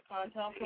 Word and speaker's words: COINTELPRO: How do you say it? COINTELPRO destroyed the BPP. – CONTELPRO. – It's pronounced CONTELPRO.